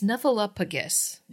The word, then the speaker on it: Snuffleupagus: I need to point out that Rory says she didn't know if Lucy's boyfriend was real or if he's her snuffleupagus. It is snuffleupagus.